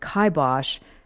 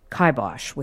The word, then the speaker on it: kibosh: Now not everybody, at least in the United States, even recognizes the phrase, and those who do tend to pronounce it kibosh as though it was kibosh with two B's.